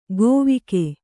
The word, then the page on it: ಗೊವಿಗೆ: govige